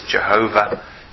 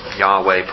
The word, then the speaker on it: Yahweh: or you'll hear it mispronounced on the lips of Jehovah's Witnesses. Jehovah, it's actually Yahweh, probably more likely, something like that.